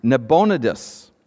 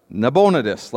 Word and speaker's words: Nabonidus: Well, archaeological discoveries came along, and what they found was that the last Babylonian emperor was, let me get his name right, Nabonidus. All right, Nabonidus, let's go with that.